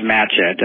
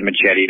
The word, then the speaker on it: Matchette: You had said Machete. Don't worry about it. That's very common. We used to go out and be with my grandfather, and the last name is spelled M-A-T-C-H-E-T-T-E. So think about Marquette, Matchett.